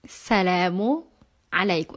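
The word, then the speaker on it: Salamu alaikum (سلامو عليكم): Salamu. Alaikum. Actually, some people, they don't pronounce is. They pronounce it salam. Salamu alaikum. Salamu alaikum. Repeat after me. Salamu alaikum. There is ayn.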